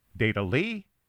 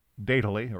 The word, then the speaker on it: Datally: Should it be Dat-Ally? Datally? Datally? Datally? Well, whatever we call it, and I think I'll call it Datally from now on, we can't call it new because the concept has been around for quite a while.